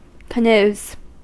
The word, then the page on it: canoes: [kəˈnuːz]; American English